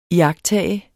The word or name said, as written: iagttage